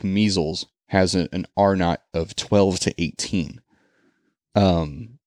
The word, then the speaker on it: R-naught: It's pronounced A-R-E-N-O-T, R-naught.